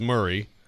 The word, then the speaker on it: Maury: Maury is Murray, and Santa Fe in Murray County.